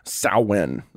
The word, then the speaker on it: Samhain: But the earliest origins are actually from before the time of Jesus in a Celtic festival known as Samhain. That's how it's pronounced in the Gaelic.